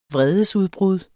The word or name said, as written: vredesudbrud